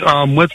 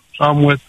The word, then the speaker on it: Amouet: Mais Amouet, comme ça se prononce, parce que l'écriture est tellement fine. – C'est Amouet, c'est ça ? – Amouet, Amouet, oui.